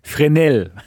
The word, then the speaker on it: Fresnel: wo ich auch mit meinen Französischkenntnissen hier angeben kann, Fresnel wird ausgesprochen.